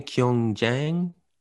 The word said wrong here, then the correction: Gyeongju: Gyeongju. – Gyeongju. Gyeongju.